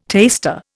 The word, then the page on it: taster: Indian